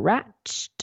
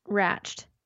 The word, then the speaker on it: Ratched: It's called Ratched. – No, it's called Ratchet. – R-A-T-C-H-E-D. Is that how you say it, though?